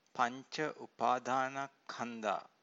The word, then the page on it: pancaupadanakkhandha: Pali